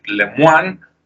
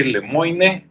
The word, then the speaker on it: Lemoine: Esta, esta foto que están viendo en internet es de, este, depende cómo lo pronuncien, ¿verdad? Para mí es Blake Lemoine, pero puede ser Blake Lemoine, para los que no saben otros idiomas.